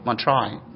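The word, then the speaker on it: Montreuil: Fruit walls of Montreal, am I pronouncing it right? Montreal. Thank you for helping.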